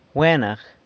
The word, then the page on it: Gwenag: sanlucasquiavini